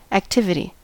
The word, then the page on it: activity: American English